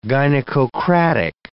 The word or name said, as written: gynecocratic